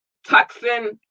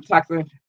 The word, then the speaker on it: Tucson: Tucson, Arizona, I said it right this time, Tucson.